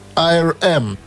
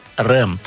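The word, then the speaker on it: R.E.M.: Эта группа называется IRM, ну или как у нас в простонародье говорят, РЭМ.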